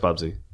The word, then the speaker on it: Bubsy: Bubsy? – No, Bubsby? No. He said Bubsby, and he meant it. – Okay. – I think his name is Bubsby. – No, it's Bubsy.